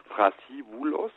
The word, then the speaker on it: Thrasybulos: Im Griechischen bedeutet der Name aber letztendlich Thrasybulos, also mit TH oder mit dem englischen TH ausgesprochen und mit einem B drinnen.